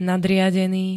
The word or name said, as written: nadriadený